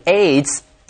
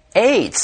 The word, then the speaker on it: AIDS: And we should not pronounce the acronym AIDS, A-I-D-S. It should be AIDS. – That's right. It should be pronounced like a word, AIDS, not spelled out, because it's an acronym, which, by the way, stands for Acquired Immune Deficiency Syndrome.